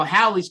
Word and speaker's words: Halley's: Well, Haley's Comet is now Halley's Comet with a short A and a double L. It doesn't even match Bill Haley in a single L long A.